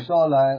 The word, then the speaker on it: Uppsala: Uppsala.